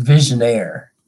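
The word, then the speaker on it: Vigenère: It's pronounced visionaire. – Visionaire. – So, the visionaire cipher.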